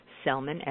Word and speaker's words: Sellman: And if you are, please go over to my website, Dr. Cheryl Selman, and I'll just spell that because it's not your normal Cheryl. It's S-H-E-R-R-I-L-L, Selman, S-E-L-L-M-A-N.